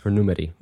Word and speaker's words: Nvmeri: So thumbs up, two thumbs up for Numeri. Which is spelled, by the way, NVMERI.